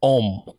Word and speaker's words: homme: It's like there's an H in the front, but it's homme. You don't really pronounce homme.